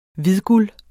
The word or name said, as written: hvidguld